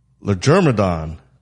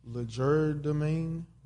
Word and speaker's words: legerdemain: All right, so the word of the week is Legermadon. Legermadon. So what you think? Well, you gonna see how it's pronounced. – No, I'm not looking at that part. – Oh. – Legerdemain.